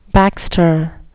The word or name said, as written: baxter